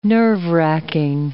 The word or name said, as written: nerve-wracking